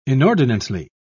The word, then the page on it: inordinately: British English